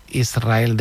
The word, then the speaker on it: Israel: Y la S con la R es difícil de pronunciar. La gente dice Israel, es verdad. – Que suene la S.